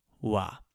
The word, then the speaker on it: は: It's the topic of the situation, and it's always marked with that funny little ha symbol that's pronounced wa.